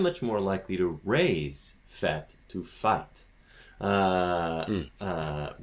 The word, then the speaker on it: fête: Now, "fête" is uh, F E circumflex T E, and "sait" he knows the T is silent. In fet, the T mm-hmm. is not silent. And so the E is longer.